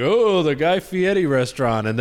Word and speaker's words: Guy Fieri: Guy Fieri. – Ooh, you said it right. Good for you.